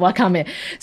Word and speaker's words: wakame: Wakame, which is wakame. It looks like wakame. – It does. It does. But so it's not pronounced like that. – It's not pronounced like that. It's wakame.